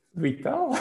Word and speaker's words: vital: Vital, V-I-T-A-L, but in English pronounced vital.